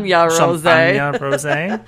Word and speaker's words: Rosé: It's called Champagne Rosé, or if you pronounce it like Champagne it's said Rose. In the song, it's Champagne Rosé.